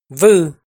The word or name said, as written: Vwj